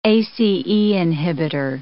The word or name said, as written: ACE inhibitor